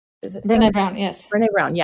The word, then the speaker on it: Brené Brown: And Brené Brown, I think this is how you say her name. – Brené Brown.